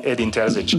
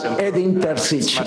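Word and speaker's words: Edin Terzić: Me gustaría que nos dijeses cómo se dice bien tu nombre, y después que nos hables de la importancia que quieres que tenga Óscar de Marcos en tu staff. – You can call me Edin Terzic. – Me puedes llamar Edin Terzic. Muy sencillo, es mucho más fácil que en euskera, así que es fácil pronunciarlo.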